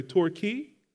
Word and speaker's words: Torquay: Torquay.